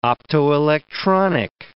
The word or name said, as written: optoelectronic